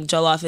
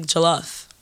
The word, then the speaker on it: Jollof: It's not like Jollof. It's like Jollof. Jollof rice. – Jollof rice. – Almost like J-U-H.